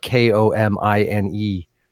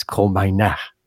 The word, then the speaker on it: Komine: Um, and, and you, you, you, um, helped us to understand that the right way to say the name that is spelled K O M I N E is Komina. I believe that would be somewhat close to the Japanese pronunciation. I'm probably completely wrong. Uh, phonetically, it would be ko mi ne.